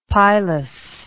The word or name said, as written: pilous